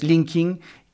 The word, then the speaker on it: LinkedIn: Pues LinkedIn es una de esas, eh, de esas palabras. Ya sabéis que, bueno, en inglés linking,